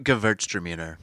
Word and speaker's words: Gewürztraminer: Reserve Gewürztraminer. – Did I say that right? – Ooh, I think you did.